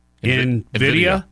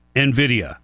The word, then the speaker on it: NVIDIA: The NVIDIA? – Yeah, just like an N. Yeah. – NVIDIA.